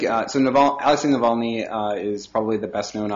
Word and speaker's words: Navalny: Is that the way you pronounce it? – Yep, Navalny. Yeah.